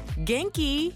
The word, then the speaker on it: genki: Kinky. – Non, kinky, Janai. Genki. – Ah, genki.